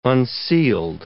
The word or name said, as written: unsealed